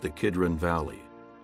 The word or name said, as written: Kidron Valley